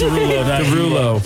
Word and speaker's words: Derulo: Ride and Solo, Jason Derulo. Did I say his name? – Yes. – Derulo.